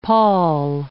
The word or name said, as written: pall